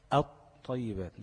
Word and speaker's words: الطيبات: تكتب ولا تنطق، يبقى كلمة الطيبات هتنطق الطيبات مش ال، الطيبات، عملت انا ايه؟ قلبت اللام لطاء وأضغمت الطاء دي في الطاء دي، ماشي؟